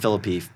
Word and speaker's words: Phillippe: It's about Ryan Phillippe, and I'm going to go with Phillippe. That's how we say his name in my book.